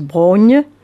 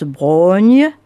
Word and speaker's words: Braunhie: La Brogne, alors que ça s'écrit B-R-A-U-N-H-I-E, la Brogne, dites-vous. Nom hirsute et bizarre au premier regard, mais familier à tous les Quercinois. On le prononce Brogne, et se lèvent alors des chaînes sans fin, tenaces, tordues, une dense forêt venue tout droit d'avant, même l'histoire.